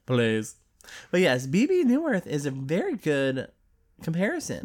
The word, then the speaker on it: Bebe Neuwirth: Is that how you pronounce her name? Oh, Bebe Neuwirth? Yes.